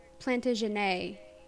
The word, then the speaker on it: Plantagenet: Also, the word Plantagenet, I believe is how you pronounce it. It's all French sounding. But you said Plantagenet or something like that. And I believe it's one of those with the French soundingness where you pronounce the ET as an A.